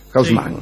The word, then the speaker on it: Haussmann: En 1853, el día en que Haussmann prestó juramento, digo la pronunciación que se usa en París, Haussmann. Sí.